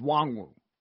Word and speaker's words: Nwangwu: And the running back from Iowa State, Kene Nwangwu, correct? Is that how you pronounce uh, the, it? I... The N is not said, so it's just Wangwu.